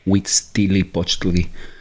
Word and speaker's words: Huitzilopochtli: No, it's, it's an H, silent H in Spanish. H U I. We. Huitzilopochtli.